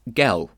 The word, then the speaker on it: girl: Gel was the way he pronounced girl.